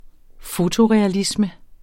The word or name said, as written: fotorealisme